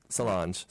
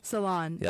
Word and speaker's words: Solange: Solange. – Solange.